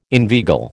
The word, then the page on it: inveigle: American English